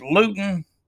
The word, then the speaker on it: Luton: But the Pittsburgh game, it tells you how important quarterback play is because, I mean, let's face it, Luton is somebody with the NFL Network called him, kind of rhyme with crouton, right?